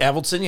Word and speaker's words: Avildsen: Avildsen? I don't know how to pronounce it. – Avildsen, yep.